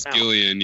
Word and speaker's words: Gillian: Is that how you say her name? Gillian or Jillian? – Yeah, I don't know. – It's Gillian, yeah.